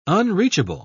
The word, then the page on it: unreachable: British English